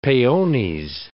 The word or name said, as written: peones